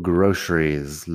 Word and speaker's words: groceries: Tienes que verlo por escrito porque la pronunciación es un poco ah, exótico, pero yo digo groceries. La C en medio como si fuera SH.